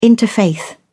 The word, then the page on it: interfaith: British English